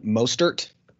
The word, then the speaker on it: Mostert: All right, so my first one here is Raheem Mostert.